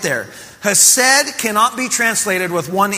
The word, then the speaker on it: Hesed: That word and that concept is spelled H-E-S-E-D, pronounced Hesed.